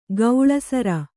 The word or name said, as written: ಗೌಳಸರ